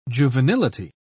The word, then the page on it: juvenility: British English